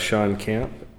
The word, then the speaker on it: Shawn Kand: Sean Camp. S-H-A-W-N-K-A-N-D.